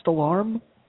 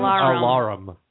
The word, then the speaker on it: Alarum: Yes, isn't it pronounced Alarm? What are you, Tom Brokaw? – No, it's Alarum. – There's a U in there.